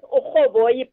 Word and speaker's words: urhoboyi: Yes, Uhoboyi, but it's spelled as in URHOBOYI.